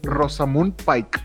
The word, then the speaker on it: Rosamund Pike: Rosamund Pike,